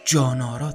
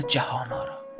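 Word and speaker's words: جهان‌آرا: محمد رو جانارا تلفظ میکنه نه جهانارا جانارا گفتنش جون میده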